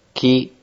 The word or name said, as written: ki